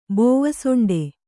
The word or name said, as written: ಬೋವಸೊಂಡೆ